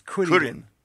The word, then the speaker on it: Krillin: When the character's name is not Krillin, it's Kurin.